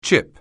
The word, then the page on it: chip: anglais